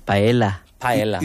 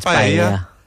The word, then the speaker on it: paella: Why, if pronounced paella, why put two L's when it should be two Y's or two H's? Paella. – I suppose it depends on the language it originates from and how that letter is particularly said in the language.